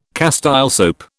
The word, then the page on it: Castile soap: British English